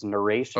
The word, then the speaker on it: Naresh: That's actually, no, that's very close. It's Naresh.